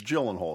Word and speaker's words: Gyllenhaal: Now, speaking of pronunciation, it's Gyllenhaal is how you say it, right? – Gyllenhaal.